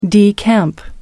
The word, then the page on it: decamp: American English